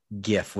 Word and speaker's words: GIF: Final answer. GIF with a hard G.